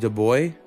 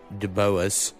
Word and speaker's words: Dubois: So, uh, all right, the name of the artist I will say is pronounced Suave de Boy. – Ooh, Suave. – Or Suave de Boy. Because uh, it's spelled S U A V E and then D U B O I S. And I know it's not De Boas.